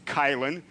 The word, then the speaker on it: Kylan: Now my name, by the way, it's Kylan. It's spelled K-Y-L-A-N, Kylan, all right?